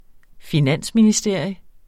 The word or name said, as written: finansministerie